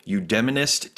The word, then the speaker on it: eudaemonist: And I don't even know if I can pronounce this correctly, but you describe yourself as a eudaemonist democratic pragmatist.